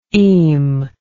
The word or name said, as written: eme